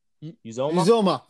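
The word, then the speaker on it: Uzomah: How do you say, is it CJ Uzoma, the tight end? – Uzoma. – There you go. You got it. That sounded good.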